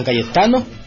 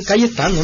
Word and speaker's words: Cayetano: San Cayeta decía él. Él no, lo, pronunciaba. Se lo agregaba a las otras palabras, hombre. Es San Cayetano, hombre.